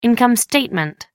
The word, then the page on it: income statement: American English